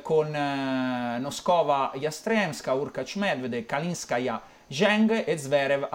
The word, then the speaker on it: Kalinskaya: Anna Nikolaevna Kalinskaya. – Kalinskaya. – Anna Nikolaevna Kalinskaya.